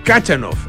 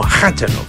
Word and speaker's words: Khachanov: Kachanov, eso, es Kachanov. Eh, eh, es que es, como Hachanov. – Se escribe con J, veo. – Con, o con KH lo escribe.